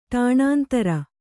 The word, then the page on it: ಟಾಣಾಂತರ: ṭāṇāntara